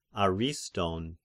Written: Ariston

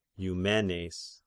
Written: Eumenes

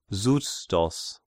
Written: Xustos

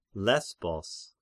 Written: Lesbos

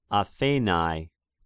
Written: Athenai

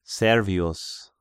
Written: Servius

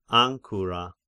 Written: Ancyra

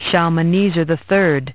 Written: Shalmaneser

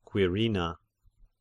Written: Quirina

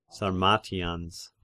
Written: Sarmatians

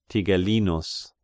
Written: Tigellinus

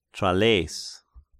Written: Tralleis

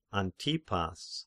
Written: Antipas

